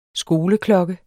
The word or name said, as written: SKOLEKLOKKE